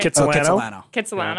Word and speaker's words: Kitsilano: I guess I went to, like, the Kitsilano Beach yesterday. Am I saying that right? – Kitsilano, yeah.